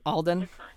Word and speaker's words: Alden: And Harrison Ford has such a charm to him that, I do think Aiden, or how do you pronounce it? – Alden. – Alden. Okay, thank you. I was like, I can't tell if my notes is an L or an I.